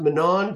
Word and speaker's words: Manan: We're staying, I believe you pronounce it Manan, Grand Manan Island.